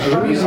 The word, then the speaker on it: жалюзи: Кураж, значит, багаж, жалюзи, да. Многие говорят жалюзи. Да, но жалюзи, потому что во французском правильно ударение на последний слог.